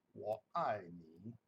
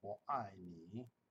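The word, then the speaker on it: Wo Ai Ni (我爱你): In Chinese, I love you is pronounced Wo Ai Ni, Wo Ai Ni.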